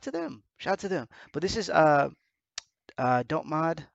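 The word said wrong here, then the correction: Dotemu: They brought about, oh, they're also bringing out, oh no, I'm sorry, it's not Dot Mod, it's Dot Mu, it's Emu. – Dot Emu. I never pronounce that right.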